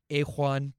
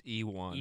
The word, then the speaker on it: Ejuan: And then another outside linebacker out of Pittsburgh called Ajuan Price. Ejuan? Ejuan? I don't know if the J is silent. – How do you spell it? – E-J-U-A-N. – It's Ewan.